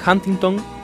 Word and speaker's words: Huntington: Y finalmente Huntington, sí, está bien pronunciado, me dicen por interno, quien ha participado de Superman, será el hombre lobo Yoch de este remake de la serie Being Human.